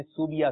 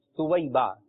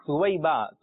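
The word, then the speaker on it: ثویبہ: اچھا اس نام کا جو تلفظ جو ہے لوگ بگاڑ دیتے ہیں اور کہتے ہیں سوبیا سوبیا, یہ زیادہ بولا جاتا ہے. تو اس کا درست تلفظ ہے سویبہ. کیا ہے؟ سویبہ.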